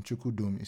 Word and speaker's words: Chukwudum: Chukudum is spelled CHUKWUDUM.